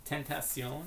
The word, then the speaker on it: Tentacion: Tentacion? – Yeah.